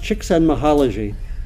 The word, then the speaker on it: Csikszentmihalyi: It's pronounced Csikszentmihalyi.